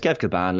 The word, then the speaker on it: Kilbane: We'll ask Kev. Kev Kilbane. It's Kev Kilbane.